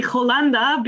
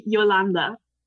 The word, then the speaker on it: Jolanda: And the Spanish people, they would say Jolanda because it's written with J. So I've had a lot of very different pronunciations of my name, but the way we say it in Switzerland would be Yolanda.